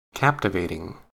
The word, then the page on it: captivating: American English